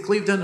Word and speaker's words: Clevedon: Is Clevedon, is it Clevedon? Am I saying that right? – You are indeed, yeah.